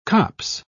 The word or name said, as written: copse